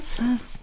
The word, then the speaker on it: sus: Ja, also, Auflösung SAS wird es ausgesprochen. – Sass.